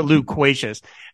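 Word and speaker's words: Lou Quacious: Plaz learns that Chatterbox was working with a local radio show host, Lou Quashus. And it took me a second to get that. I guess I should pronounce it Luquacious.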